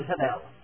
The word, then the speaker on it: hebel: He uses a little word, hebel. H-E-B-E-L. Sometimes the B in Hebrew sounds like a B when it's pronounced.